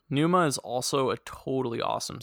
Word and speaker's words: pneuma: In Greek, the word pneuma, which has a silent P at the beginning, can mean breath, spirit, or soul.